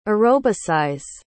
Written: aerobicize

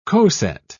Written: coset

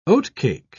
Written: oatcake

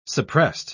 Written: suppressed

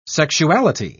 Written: sexuality

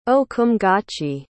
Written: ohcumgache

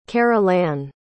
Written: carolanne

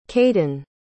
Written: kayden

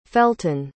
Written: felton

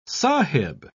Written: sahib

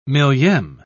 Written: millieme

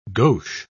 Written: gauche

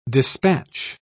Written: dispatch

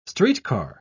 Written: streetcar

